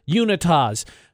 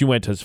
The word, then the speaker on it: Uintas: People, Unitas. Doesn't matter who it is. It's Uintas, for the record.